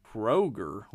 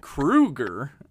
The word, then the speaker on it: Kroeger: You might be tempted to say Kroger, like the grocery store chain. But it is, in fact, Kroeger, like the Nightmare on Elm Street icon. Kroeger.